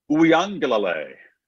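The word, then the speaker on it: Uiagalelei: Uyangalale.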